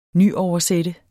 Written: nyoversætte

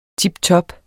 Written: tiptop

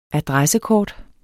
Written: ADRESSEKORTET

